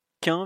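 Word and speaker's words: Kean: Alors, euh, on me dit que ça se prononce Kin, visiblement. Alors, je, sais pas. – Mathieu a dit c'est Moïse Kin.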